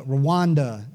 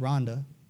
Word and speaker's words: Rwanda: Go to India and go to Cambodia and go to, the Rwanda. It's actually pronounced Rwanda. Rondon told me.